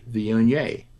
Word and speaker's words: Viognier: So to combat this, the American producers of Viognier adopted a slogan that is, just say Viognier. And they hope that'll help you remember how to pronounce it.